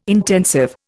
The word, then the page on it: intensive: Indian